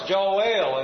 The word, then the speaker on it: Joel: And when you look at the word, we just say Joel, but if you have a self-pronouncing Bible, you'll see that it's divided up in at least two parts, Joel.